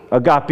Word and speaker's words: agape: Agape. Did I say it right? – Agape.